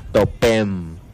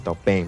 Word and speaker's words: topem: Eso es lo que hace la diferencia, por eso es que decimos topem. – Y en la normal, en la castellana es que lleva el acento en la E.